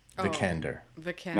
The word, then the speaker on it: Vikander: Vikander.